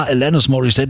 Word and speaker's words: Alanis Morissette: Alani Morissette? Was ist das? Alanis? Wie spricht man die denn aus irgendwie, ne? Bis dann irgendwann hieß, ja, Alanis Morissette, die kommt aus Kanada, englische Aussprache und so.